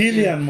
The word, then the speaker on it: Cillian: Cillian Murphy se y eso lo acabo de aprender. Ah, sí. sí, se pronuncia. Cillian Murphy.